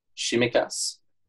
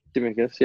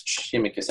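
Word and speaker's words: Tsimikas: But yeah, I just think a special shout-out must go to, and I didn't realise it was pronounced like this, but Shimikas. – Is that how it's pronounced? Wow. – You want to put on that one? How's it pronounced? – Timicus, yeah, he's right. – Simicus? – Timicus. – Oh, yeah, nice. Well, you'll actually find it's Shimicus, just next time.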